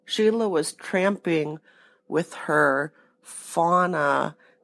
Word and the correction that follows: whānau: But whānau. – Whānau. – Fauna, which is what you said. – Whānau. – Whānau. No R.